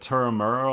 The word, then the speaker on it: Turramurra: So back home for you, of course, is, correct me if I'm saying this wrong, but Turramurra, Australia. – Yeah.